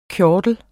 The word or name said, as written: KJORTLERNES